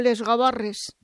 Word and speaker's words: Gavarres: Ay, mira, yo le he dicho Les Gavarres. – No, no, está bien. A ver, se escribe así, Gavarres, pasa que la diferencia de pronunciación no, ya, ya. – No es problema.